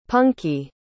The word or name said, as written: punky